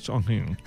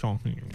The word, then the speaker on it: Chongqing: Perdó, com és la pronunciació? – Chongqing. – Chongqing. – Vas, ho he de fer molt nasal. Chongqing.